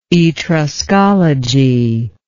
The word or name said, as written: Etruscologist